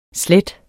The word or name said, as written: slet